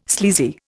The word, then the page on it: sleazy: Indian